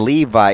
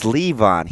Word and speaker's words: Le'Veon: It's not even Levi. It's Levon. He's just a moron, so he didn't say it right.